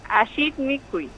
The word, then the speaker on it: Ajit Mikuy: Que nos comente acerca de este proyecto, Alit Mikuy. ¿Está bien dicho así como lo acabo de pronunciar? – Ajit Mikuy. – Ahí está, lo decimos Ajit bien.